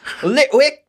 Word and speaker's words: Litwick: Litwick! That's how they say it here, yeah. – That's how they say it in Galar.